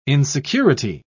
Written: insecurity

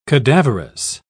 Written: cadaverous

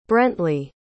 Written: brently

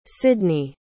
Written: cidney

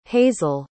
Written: hazle

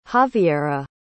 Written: xavierra

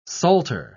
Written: salter

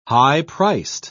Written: high-priced

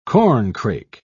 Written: corncrake

